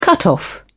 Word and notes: cutoff: British English

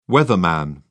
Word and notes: weatherman: British English

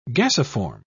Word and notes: gasiform: British English

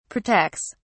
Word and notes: protects: British English

